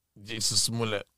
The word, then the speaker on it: Jussie Smollett: Is it Jussie Smollett? – Jussie Smollett.